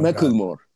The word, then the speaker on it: Macklemore: Macklemore. Özgür Filistin açıklaması. – Macklemore galiba. – Macklemore.